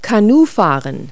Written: canoeing

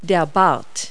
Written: wattle

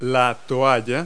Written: towel

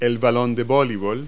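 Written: volleyball

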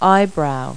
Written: eyebrow

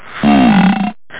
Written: say it

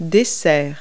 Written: dessert

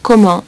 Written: comment

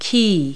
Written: quay

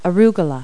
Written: arugula

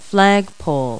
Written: flagpole

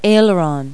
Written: aileron

